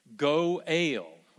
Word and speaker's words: Goel: The word here is Goel, so it's G-O-E-L. E-L, but if, I mean, I guess if you took the word for a basketball goal, G-O-A-L, and you made it two syllables instead of saying goal, you say go, and then for the A-L, you make it a long A, go-ale. When you pronounce that, that's what this word is.